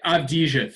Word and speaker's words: Avdija: Just say Avdija. Think of it like you're Brad Pitt and Inglourious Basterds and just say it phonetically, and it works. Avdija.